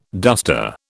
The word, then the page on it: duster: British English